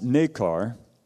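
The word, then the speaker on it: nacre: And it's interesting that that fluid that's used to coat the irritant, layer upon layer, that produces the pearl, it's pronounced nekar.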